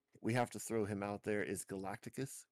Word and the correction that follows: Galactus: Can I say that right? – Galactus, there it is.